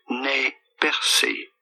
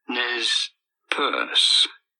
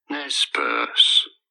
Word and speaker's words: Nez Perce: For reference, in French, this is normally pronounced as Nez Percé or Nez Percé. In English, however, this is usually said as Nez Perce, Nez Perce, Nez Perce.